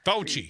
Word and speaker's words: Fauci: I think what the Republicans did, the Fucci, how do you say that name? – Fauci.